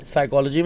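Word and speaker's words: psychology: साइकोलॉजी में? – P.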